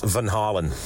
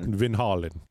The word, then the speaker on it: Van Halen: Well, I think you're a little long on that first O. This is the proper pronunciation. – Okay. – Apparently, it should be pronounced Van Halen. – Just like Kelsey said, Van Halen.